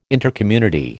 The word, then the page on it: Intercommunity: American English